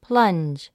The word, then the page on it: plunge: plʌ́ndʒ; プランジ